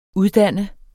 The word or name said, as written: uddanne